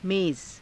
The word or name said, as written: meza